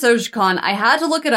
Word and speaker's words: Tsojcanth: So, Igwilv was first introduced in Gary Gygax's The Lost Caverns of Sojcon. And Travis and Lewin have some of my notes before them, so they might notice that it's spelled... T-S-O-J-C-A-N-T-H, and it's pronounced Sojcon.